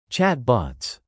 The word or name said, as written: chatbots